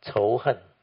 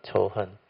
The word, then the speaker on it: 仇恨: In Mandarin, it's pronounced as 仇恨. Those who know Mandarin, they will know, 仇恨.